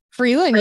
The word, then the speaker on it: Frelin: Frelin, yeah, you got it.